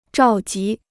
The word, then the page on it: 召集: zhào jí